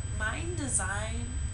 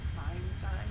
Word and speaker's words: Mndsgn: If you don't know who Mind Design is, I used to pronounce it as Mind Sign because there's, like, no vowels in his name. It's, like, M-N-D-S-G-N. So, it's, like, Mind Design? Mind Sign?